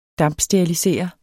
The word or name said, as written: dampsterilisere